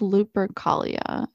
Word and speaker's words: Lupercalia: but I think it's Lupercalia is the holiday.